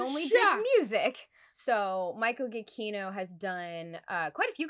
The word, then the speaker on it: Giacchino: It is Giacchino. – What did we say? – I was calling the boy Giacchino. I was butchering this man's last name. It looks like Giacchino. It looks like cappuccino or something.